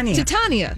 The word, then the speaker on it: Titania: Well, Jamila Jamil is going to play the villain, reportedly playing a character called, Titania, maybe that's how you say it. – Sure. – I'm sorry, Marvel. – Oh, Tatiana. – Titania.